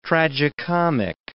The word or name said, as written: tragicomic